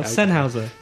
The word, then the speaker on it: Sennheiser: Sennheiser.